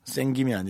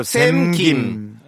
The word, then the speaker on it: 샘김: 노 눈치. 생김이 아니라. 생, 구운김 아니고 생김, 생김 아니고. 생김 아니고 샘김.